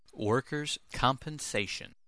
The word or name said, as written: workers compensation